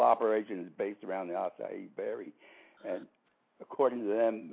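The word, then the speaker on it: açaí: It's spelled what, like A-C-A-I? – Yes, A-I. – Uh-huh. – You know, it's pronounced in a Portuguese way, A-C-I-E.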